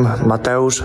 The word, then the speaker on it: Mateusz: I'm Mateusz Rembetsky. – All right, so it's spelled, you know, Mateusz, right? We all know Mateusz Gamrot, so certainly Poland It's Mateusz. When it's M-A-T-E-U-S-Z, and Cody